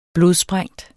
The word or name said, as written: blodsprængt